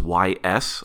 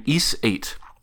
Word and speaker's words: Ys: I'm still never sure quite how to pronounce the title because it's spelled just Y-S. I believe it is Ys. But I'm playing Ys 8.